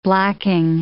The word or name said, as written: blacking